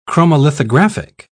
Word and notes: chromolithographic: British English

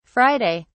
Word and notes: friday: British English

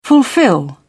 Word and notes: fulfill: American English

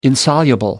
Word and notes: insoluble: American English

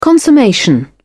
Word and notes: consummation: British English

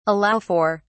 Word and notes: allow for: British English

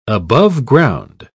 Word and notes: aboveground: British English

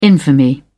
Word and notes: infamy: British English

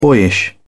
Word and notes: boyish: British English

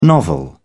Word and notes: novel: British English

